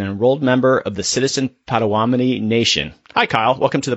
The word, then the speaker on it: Potawatomi: The proper pronunciation is Potawatomi.